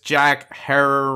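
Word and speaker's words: Herer: So, of course, we're talking about the legendary author and activist Jack Hare, rhymes with terror.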